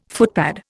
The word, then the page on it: footpad: Indian